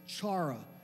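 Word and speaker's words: chara (חרה): It's chara, if you were to pronounce it. It's pronounced differently, but it's C-H-A-R-A-H.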